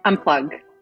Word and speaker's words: Unpluq: We talked about this briefly before, but how do you pronounce the name of the company? – Unplug. – As if it were a G.